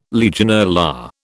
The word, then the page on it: Legionella: British English